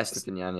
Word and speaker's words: Estupiñán: And then, you know, we're looking at some of the players, like a bunch of Brighton players, Estupiñán, or how do you say his name? – Yeah, Estupiñán, yeah.